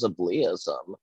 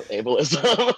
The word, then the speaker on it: ableism: I thought it was ableism. And I was like, well, it's from the word able, right? So ableism, good try though.